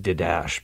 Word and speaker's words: Didache: And it's spelled D-I-D-A-C-H-E. So it kind of looks like Didache, but it's actually Didache. That's how it's pronounced.